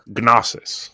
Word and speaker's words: gnosis: Gnosis. – Gnosis. It's like Gnocchi.